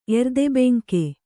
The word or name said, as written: ಎರ್ದೆಬೆಂಕೆ